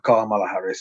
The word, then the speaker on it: Kamala Harris: Nu säger jag inte att det var, så var fallet med Kamala Harris, men att, eller Kamala Harris ska det väl uttalas, ja.